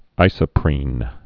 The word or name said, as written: isoprenoid